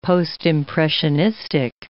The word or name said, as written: Postimpressionistic